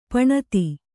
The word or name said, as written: ಪಣತಿ